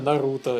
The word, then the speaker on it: Наруто: счастлив, а потом оказалось, что вот официально Наруто э, я вот только когда ролики делал, и делал эту базовую озвучку, по которой потом уже профессиональные актеры все переозвучивали. Ага. Я сначала Нарутон, Нарутон, Нарутон, потом, бля, тут официально не так, а просто последние раза три уже Наруто в комментариях э, к тексту, типа, произносить на, на средний слог.